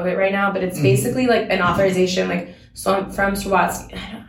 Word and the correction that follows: Swarovski: I say the name wrong. It's really hard to say. – Swarovski. – Yeah, see, it's hard.